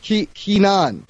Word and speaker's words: Kenan: You pronounce it Kenan. K-E-N-A-N.